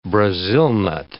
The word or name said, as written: Brazil nut